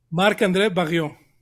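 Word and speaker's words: Barriault: Ayer me metí justamente al sitio de prensa para escuchar la pronunciación. Como si dijeras barrio, pero en francés. – Barrio. Marc-André Barrio.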